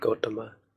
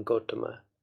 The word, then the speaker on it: Gotama: Pali, the convention for Pali in Sanskrit is to put the stress on the penultimate syllable, which is the second to last. So in this case, that would be the first A in Gotama.